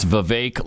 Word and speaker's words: Vivek: So I guess Vivek, but I understand it's Vivek like cake.